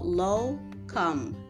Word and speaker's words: lokum: It's called lokum, L-O-K-U-M, lokum, which is a Turkish treat.